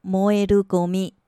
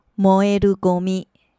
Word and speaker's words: 燃えるゴミ: 我们先来学“ 垃圾”这个单字，日文念成“ゴミ”，ゴミ，ゴミ，可燃垃圾叫做燃えるゴミ，燃えるゴミ，燃えるゴミ。